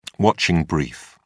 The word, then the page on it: watching brief: British English